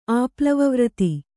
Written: ಆಪ್ಲವವ್ರತಿ

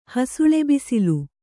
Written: ಹಸುಳೆಬಿಸಿಲು

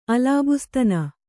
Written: ಅಲಾಬುಸ್ತನ

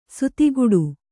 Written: ಸಾತಗಡಿ

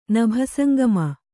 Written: ನಭಸಂಗಮ

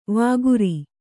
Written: ವಕ್ರಿ

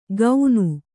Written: ಗೌನು

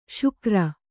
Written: ಶಿಖರಿ

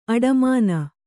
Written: ಆಡುಮನೆ

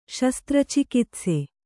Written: ಶಸ್ತ್ರಚಿಕಿತ್ಸೆ

